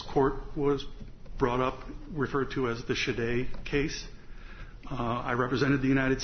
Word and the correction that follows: Chaidez: States in that case, and I'm informed by counsel for the petitioner that her name is pronounced Chaidez.